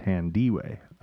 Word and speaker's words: Thandiwe: But now Newton is correcting the error, telling British Vogue that it is Thondwe. – Tandy Way. – I think. Tandy Way. That's my full name, and this is from her talking to Vanity Fair. T A N is the pronunciation.